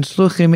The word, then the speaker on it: Shluchim: Shluchim is the common pronunciation of Shlichim, the term used for Chabad, ultra-Orthodox emissaries.